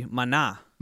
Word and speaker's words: Maná: Mañana. – M-A-N-A with the accent over the A, that would be Maná. – Maná. – Maná. – Maná. – Are you going to see Maná?